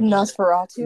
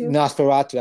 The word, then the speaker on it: Nosferatu: Nafastafu. – You know that Nosferatu. – Nosferatu.